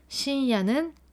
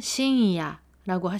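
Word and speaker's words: 深夜: 발음을 우리나라 발음의 이응으로 해서도 안 되고 니은으로 해도 안 되는 뭔가 응인데 목구멍 닫다 말고 다음 발음하는 것 같은 그런 분위기예요. 그래서 신야, 신야 둘 다 안 돼요. 발음은 신이야라고 하시면 됩니다. 일본어 응도 박자 한 박자를 차지하게 발음을 해주세요.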